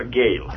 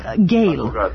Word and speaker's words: Gehl: E se baš tako kaže, Gehl, ali tako? – On se izgovara Gehl. – Gehl.